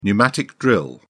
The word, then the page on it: pneumatic drill: British English